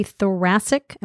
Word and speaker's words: thoracic: So is a thoracic, am I saying that correctly? – Yes, you are, okay, thoracic surgeon.